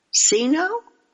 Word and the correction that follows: Cinco: Did I say that right? Jess? – Cinco. – Cinco? Cinco. – Yes.